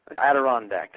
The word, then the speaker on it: Adirondack: Now, the value proposition that was submitted is get a quality brand name, Adirondack chair. I'm not sure I'm getting it right. I'm not saying I'm saying the name correctly. – Adirondack? – Adirondack, that's it.